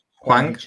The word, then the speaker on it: Hwang: Hwang.